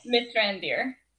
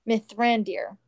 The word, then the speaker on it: Mithrandir: Mithrandir. – Mithrandir.